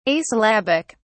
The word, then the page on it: asyllabic: British English